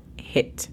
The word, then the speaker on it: HIIT: La pronunciación fonética es simplemente J-E-T. Atención, porque puede hacer una gran diferencia el cómo pronunciamos la E. La E en esta palabra es sumamente corta, es HIIT, no es HIIT, es HIIT.